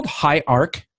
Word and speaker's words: HIARC: And he is the owner of an incorporated commercial roofing company called High Arc? Is that how you pronounce it? – Yep, you got it.